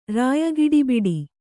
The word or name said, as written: ರಾಯಗಿಡಿಬಿಡಿ